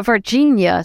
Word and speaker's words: Varginha: Yeah, Varginha is how it's pronounced.